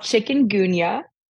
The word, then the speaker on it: chikungunya: It's called chikungunya. Yeah. It is not spelled like chicken the animal. It is it's chikungunya.